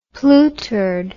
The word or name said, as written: plootered